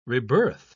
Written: rebirth

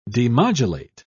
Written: demodulate

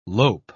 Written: lope

